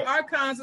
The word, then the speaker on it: archons: Now another term that you have in your book is called archons. So what how would you define the archons? I know that deals with the gods. I hope I'm pronouncing it right. – Yeah, you are because yeah. archons is a, it's a name that a lot of authors have used, and even yeah.